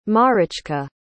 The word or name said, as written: Marichka